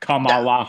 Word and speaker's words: Kamala: I think he's like almost as garbage as Trump, but I'm fucking pro Kamala Harris. Why did I say that wrong? Anyways, Yeah, a bunch of like hard A sounds there. So like Kamala Harris.